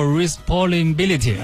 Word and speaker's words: responsibility: Sky 啊，巴山楚水凄凉地啊，然后 Responsibility 啊，这也是一个谐音梗，是不是？我没读错吧？又在这考我了啊！然后我们来听听正确读法应该怎么读啊？这是逗号姑娘发来了和她一样的留言。 – 巴山楚水凄凉地，responsibility。哦，responsibility 啊！你看看人家这发音啊，有文化就是不一样啊。